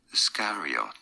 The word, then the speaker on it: Iscariot: Judas Iscariot. In British English, this is said as Iscariot. Now, in American English, it is generally pronounced as Iscariot.